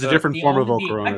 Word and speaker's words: Volcarona: Uh, yeah, Volcarona is how you say it.